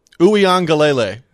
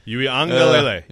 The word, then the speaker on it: Uiagalelei: Uiagalelei.